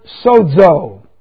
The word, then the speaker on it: sozo: Future passive indicative of the Greek word sozo. S-O-Z-O, long O.